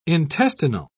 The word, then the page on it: intestinal: British English